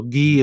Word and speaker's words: ghee: So ghee is the pronunciation before the pronunciation police arrest us on this show.